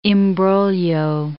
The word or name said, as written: imbroglio